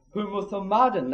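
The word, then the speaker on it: Humothomadon (ὁμοθυμαδόν): Humothomadon, that's what it's pronounced in the Greek.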